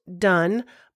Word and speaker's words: Donne: His name is pronounced Donne, but it is spelled D-O-N-N-E.